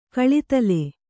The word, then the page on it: ಕಳಿತಲೆ: kaḷitale